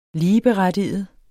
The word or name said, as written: ligeberettiget